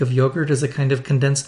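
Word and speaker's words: yoğurt: Now, the word in Turkish is also spelled with a G, like in English, but apparently G in Turkish has a very different sound. More like the English W, I guess. So it's more like yogurt.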